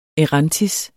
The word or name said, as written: erantis